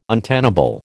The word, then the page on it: untenable: American English